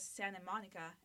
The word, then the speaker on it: Santa Monica: I always thought it was Santa Monica, and yeah. then I have yeah. like people telling me like, why are you not pronouncing the T? I'm like, because you. You just don't.